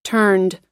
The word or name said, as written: turned